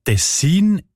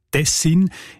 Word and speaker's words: Tessin: Die Variante Tessin ist häufiger. Die ist im Schweizerdeutschen Wörterbuch Idiotikon belegt im Kanton Bern, in der ganzen nördlichen Schweiz, aber auch im Innerschweizer Kanton Schweiz und das Siselen und das Unterschächen im Kanton Uri. Und auch im Hochdeutschen sieht man Tessin. Die Variante mit der Betonung auf der ersten Silbe und mit kurzem I, also Tessin, ist seltener.